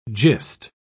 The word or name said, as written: gist